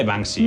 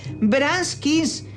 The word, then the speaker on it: Banksy: A ver, mamá, se dice Banksy. – No se dice así, se dice Braskis. ¿Qué manía tenéis con decirlo mal todos? – Banksy, Banksy.